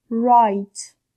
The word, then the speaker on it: Write: Write. W داخل این کلمه تلفظ نمیشه و معنی نوشتن میده.